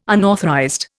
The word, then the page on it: unauthorized: Indian